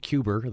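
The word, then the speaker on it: Cuba: I mean, aside from the fact that you wrote Cuber, the way he did pronounce it with the R on the end, being able to write speeches for a president that you clearly admired.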